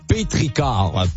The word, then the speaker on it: pétrichor: le pétrichor. Bon, c'est pas un pétrichor, là, juste à côté, c'est marqué, ça se prononce pétrichor.